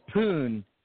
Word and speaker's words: Pune: He's from Pune. There's no R. Every time I look at that word, I see Pune. It's Pune.